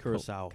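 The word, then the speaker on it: Curaçao: Curacao. – Curacao.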